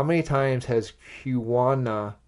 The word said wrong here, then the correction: Kuina: defeated Zoro? Kuina.